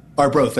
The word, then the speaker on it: Arbroath: Did I say that right? Arbroath? Am I saying? – Arbroath, that's right.